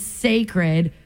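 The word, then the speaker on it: Cécred: She's calling it Sacred, pronounced, it's like sacred, like the word sacred, but it's actually spelled C E C-R-E-D. It's a play on the last syllable of her word, Beyonce.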